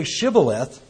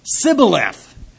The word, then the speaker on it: Shibboleth: So they can't say SH. They can't say Shibboleth. And so they would say Sibboleth, for he could not pronounce it right.